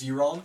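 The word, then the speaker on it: Ciaran: And while we're at it, our captain's name is pronounced Ciaron. – Ciaron. – Like, you know the like obscure fucking hip hop artist Ciara, just like that.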